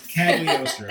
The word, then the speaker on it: Cagliostro: I can't say the name in its proper Italian. I pronounce the G. And so it's Cagliostro.